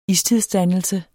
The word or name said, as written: istidsdannelse